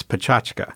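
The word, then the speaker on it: PechaKucha: It's a confusing word. It's pachachka is the way they pronounce it.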